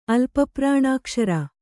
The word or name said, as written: ಅಲ್ಪಪ್ರಾಣಾಕ್ಷರ